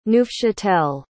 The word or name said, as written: neufchâtel